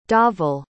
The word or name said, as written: dhaval